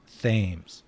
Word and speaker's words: Thames: So, Eric, it's official now on Foul Territory. Your last name is pronounced Thames.